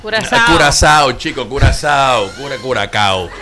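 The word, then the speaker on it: Curaçao: Óyeme, se escribe Curacao, pero se lee Curazao.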